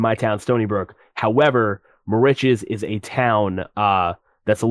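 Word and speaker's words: Moriches: So, want to talk about the song that you guys did a music video for, More Riches. Is that how you pronounce that? – Yes, More Riches, which a lot of publications are saying that's a road on Long Island.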